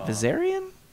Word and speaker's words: Viserion: Is it Viserion? – Yes.